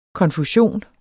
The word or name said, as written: konfusion